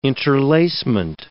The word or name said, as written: interlacement